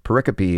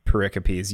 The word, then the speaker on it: pericope: Yeah, if you are talking to someone about the Bible and you find yourself talking about pericopes, you know you've gotten into a very technical debate. – And it's spelled P-E-R-I-C-O-P-E. And remember, it's the last. It's pericope. Pericope. Okay. Not a pericope.